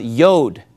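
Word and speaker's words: yod (יוד): A jot is the smallest letter in the Hebrew alphabet is pronounced yod, right?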